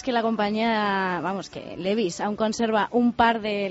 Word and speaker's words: Levi's: Sí, hoy te estamos contando además que los vaqueros Levi's acaban de cumplir 140 años, ya, 140 años. – Levi, ¿no? Los Levi's.